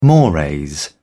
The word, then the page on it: mores: British English